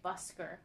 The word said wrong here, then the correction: Buster: I was saying it wrong, because his name is Buster.